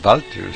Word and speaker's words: Walter: A lo mejor lo dicen en alemán, porque sea que en alemán la W se pronuncia como V, la V dental. – Ya. – Así que a lo mejor o sea, Walter en alemán es Walter, así que a lo mejor ellos lo que están diciendo eso.